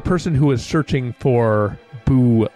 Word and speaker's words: boo: All right, Monty, you might as well explain the thing about boo in Latin. – It means I cry. Here. B-O-O, because O is first person singular. So it's pronounced bo-o, and it means I cry.